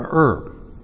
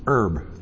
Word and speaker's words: herb: A herb. Or if you're from the East, herb. They don't pronounce the age.